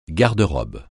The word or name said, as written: garde-robe